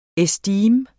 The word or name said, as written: estime